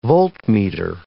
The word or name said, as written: voltmeter